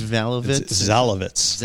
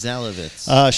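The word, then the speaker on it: Szalavitz: It's written by the author Maya Zalovitz, and I figured out the easy way to pronounce her name is to forget the S exists. – Okay, it's just Zalovitz? – Zalovitz.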